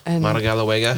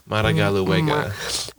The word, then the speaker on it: Madagaluega: Did I say that right? – Madagaluega. – I make like a toad face whenever I say it and just make Madagaluega. Imagine my mouth looks like the sad face with the parenthesis. Right. – Madagaluega. I mean, you're saying it right, so it works.